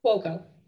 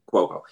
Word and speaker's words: Cuoco: Cuoco. – Cuoco.